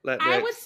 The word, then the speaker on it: Latinx: And keeping with this, you know, Latin, is that how you say it? Latin? – Latinx.